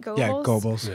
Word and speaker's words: Goebbels: You're talking to 2016's Joseph Goebbels. Am I saying that right? – Goebbels.